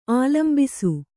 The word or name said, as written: ಆಲಂಬಿಸು